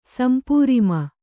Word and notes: ಸಂಪೂರಿಮ: kannada

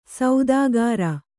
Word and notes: ಸೌದಾಗಾರ: saudāgāra

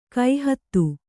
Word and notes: ಗೋಹತಿ: gōhati

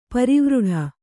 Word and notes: ಪರಿವೃಢ: parivřḍha

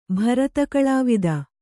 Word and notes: ಭರತಕಳಾವಿದ: kannada

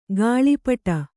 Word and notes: ಗಾಳಿಪಟ: gāḷi paṭa

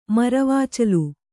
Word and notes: ಮರವಾಚಲು: Kannada

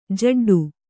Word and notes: ಜಿಡ್ಡು: kannada

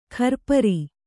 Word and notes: ಖರ್ಪರಿ: kharpari

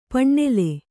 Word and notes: ಪಣ್ಣೆಲೆ: paṇṇele

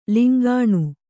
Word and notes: ಲಂಕಿಣಿ: lamkiṇi

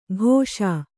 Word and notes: ಕೋಷ: kōṣa

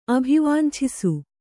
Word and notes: ಅಭಿವಾಂಛಿಸು: kannada

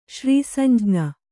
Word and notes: ಶ್ರೀಸಂಜ್ಞ: kannada

